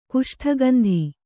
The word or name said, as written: ಕುಷ್ಠಗಂಧಿ